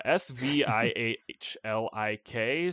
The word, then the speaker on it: Svihlik: Uh, S V I H L I K Svihlik, sure.